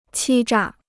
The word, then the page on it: 欺诈: qī zhà